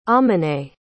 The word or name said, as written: amineh